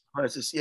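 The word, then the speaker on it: Du Plessis: Duplessis.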